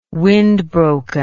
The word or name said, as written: windbroken